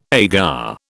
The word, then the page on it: agar: British English